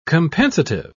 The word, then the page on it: compensative: British English